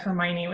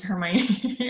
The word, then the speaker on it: Hermione: But I mean, in the same way that nobody knew that Hermione was Hermione until book four. – Oh, really? I always thought it was Hermione.